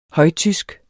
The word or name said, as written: højtysk